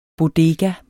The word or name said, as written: BODEGAERNES